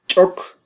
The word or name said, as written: ch'ok